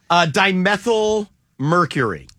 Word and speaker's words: dimethyl mercury: Does anybody know what dimethyl mercury is? Dimethyl mercury. And I hope I'm saying it right. D-I-M-E-T-H-Y-L.